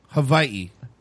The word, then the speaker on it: Hawaii: So we went to Hawaii for. – You're saying it wrong. Hawaii. – No, Hawaii. – No, Hawaii. – They have like a weird pause, like between the two eyes.